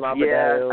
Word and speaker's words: Adebayo: Adebayo. I can't pronounce his name. – Bam Adebayo.